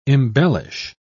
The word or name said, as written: embellish